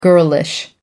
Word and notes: girlish: American English

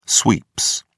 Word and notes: sweeps: British English

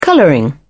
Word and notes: colouring: British English

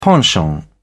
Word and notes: penchant: British English

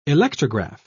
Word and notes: electrograph: British English

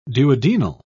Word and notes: duodenal: British English

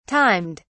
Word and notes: timed: British English

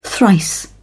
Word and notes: thrice: British English

